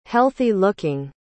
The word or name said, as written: healthy-looking